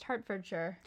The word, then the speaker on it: Hertfordshire: Which we found out is pronounced Hertfordshire.